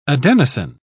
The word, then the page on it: adenosine: British English